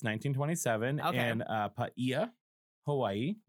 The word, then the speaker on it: Paia: Because it's spelled P-A-I-A. – Yeah. – I'll tell you, I wouldn't have pronounced it Paia.